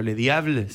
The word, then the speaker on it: Le Diable: Eh, no lo quiero pronunciar en francés, pero Le Diable se dice. Ah, Le Diablo, sí.